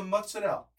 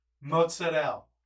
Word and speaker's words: mozzarella: You could be a little less obnoxious about it, but the Mutzadel. That's how they pronounce it, dude. Mozzarella.